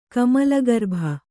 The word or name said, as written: ಕಮಲಗರ್ಭ